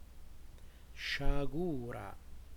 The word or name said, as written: sciagura